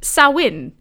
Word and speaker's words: Samhain: It's spelled like Samhain, Samhain. And because it's a Celtic word, it's not pronounced at all like it's spelled. It's pronounced Samhain.